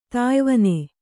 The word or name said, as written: ತಾಯ್ವನೆ